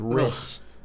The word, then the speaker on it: Rhys: Oh, but R-H-Y-S. – Oh, R-H, like in other places, Riss.